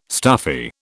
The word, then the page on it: stuffy: British English